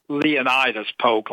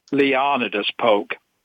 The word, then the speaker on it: Leonidas Polk: First of all, how you pronounce the name, it's not Leonidas Polk, like I hear many people saying it. It's Leonidas Polk.